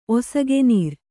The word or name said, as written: ಒಸಗೆನೀರ್